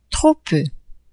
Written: trop peu

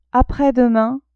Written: apres-demain

